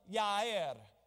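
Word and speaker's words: Jair: It says, now in Shushan, the palace, there was a certain Jew whose name was Mordecai, the son of Yair, the J is silent,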